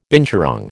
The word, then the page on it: binturong: American English